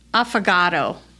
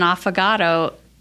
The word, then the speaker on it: affogato: But affogato. – Affogato. Is that it? Is that what it's called? – Is that how I pronounce it? An affogato? – I don't know. – You know what? It is, because I heard a lady order it in a restaurant once, and I thought she wanted an avocado for dessert.